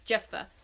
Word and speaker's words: Jephthah: And so the example I was thinking of is there was a judge, and I'm going to butcher his name, Jephthah. Jephthah.